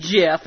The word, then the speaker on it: GIF: Well, Scarface, the message that you sent me actually came across as a GIF file, or as the proper pronunciation for Mr. Black Ratchet out there, the GIF file. I'm still calling it graphical interface. – Yeah, but it's actually pronounced GIF, and he's right, but I just hate saying the word GIF.